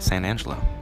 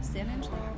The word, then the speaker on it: San Angelo: I'm saying that right, San Angelo. – Yeah, San Angelo.